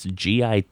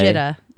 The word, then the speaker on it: Gita: How do you pronounce the name of this? It's Gita Mini. Gita. Gita. Gita. Gita. – Not anyway, Jitta. No one in the history of the world would name anything Jitta.